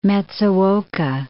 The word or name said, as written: Matsuoka